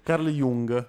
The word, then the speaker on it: Carl Jung: Carl Jung, detto giusto, ok.